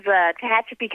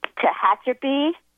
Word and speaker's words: Tehachapi: It's up in the mountains, Tehachapi, California. – Say it 3 times for me. – Tehachapi, Tehachapi, Tehachapi.